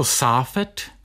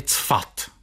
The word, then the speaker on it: Safed: No a to místo, ke kterému se takto dostáváme, je město Safed, neboli Cfat. Cefat, ono se různě prostě vyslovuje.